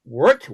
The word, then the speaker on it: Weert: Going back to 1908, Wilhelmina 08 soccer team was formed in Weert, W-E-E-R-T. How do you pronounce that? Weert. Try putting that together.